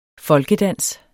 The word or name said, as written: folkedans